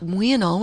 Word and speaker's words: uinal: Now, it's we and all, which is spelled U-I-N-A-L, and pronounced... We and all.